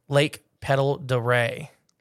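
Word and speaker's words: Pend Oreille: They supposedly have a sea monster here in late Pendleton, Orley, pronounced Lake Petal de Ray.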